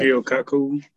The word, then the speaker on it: Michio Kaku: Well-known scientists like Stephen Hawking, Michio Kaku, I don't know if I'm pronouncing that right. – Michio Kaku.